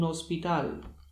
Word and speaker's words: hospital: Acie zawsze jest nieme, na przykład un hospital.